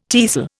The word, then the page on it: tiercel: Indian